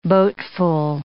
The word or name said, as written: boatful